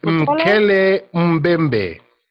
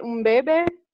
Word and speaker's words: Mokele Mbembe: Mkele, what did you say? – It's Mokele Mbembe. – Mokele Mbembe? – Mbembe. – Mbembe. – There we go.